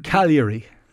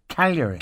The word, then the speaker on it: Cagliari: Can I, how do you pronounce it? It sounds like Calgary in Canada. – Calgary. – Cagliari. – Cagliari.